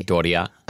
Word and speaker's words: Doria: Doria.